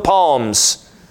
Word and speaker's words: Psalms: Somebody who pronounces that big book in the middle, the Book of Psalms. And you get the wonderful opportunity to teach them that the P is silent.